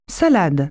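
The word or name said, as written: salad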